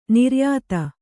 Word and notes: ನಿರ್ಯಾತ: niryāta

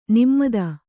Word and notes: ನಮಿತ: kannada

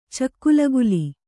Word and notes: ಚೆಕ್ಕುಲಗುಲಿ: cekkulaguli

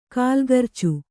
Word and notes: ಕಲಗರ್ಚು: kalagarcu

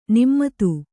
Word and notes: ನಮಿತ: namita

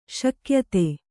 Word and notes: ಶಿಕ್ಯಿತ: śikyita

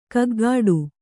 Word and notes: ಗೂಗಿಡು: gūgiḍu

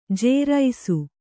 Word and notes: ಜೇರೈಸು: jēraisu

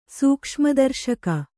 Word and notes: ಸೂಕ್ಷ್ಮದರ್ಶಕ: sūkṣma darśaka

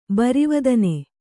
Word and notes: ಬರಿವದನೆ: barivadane

